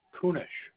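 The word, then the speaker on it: Kunesh: Kunish. – Can you spell that for me? – K-U-N.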